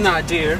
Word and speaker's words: Nadir: It's pronounced Nadir.